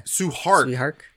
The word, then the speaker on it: Tsui Hark: It's there, and you have to understand that the director, how do you pronounce his name? – Su Hark.